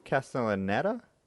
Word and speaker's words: Castellaneta: Castellaneta? – I'm fairly sure it's, yeah.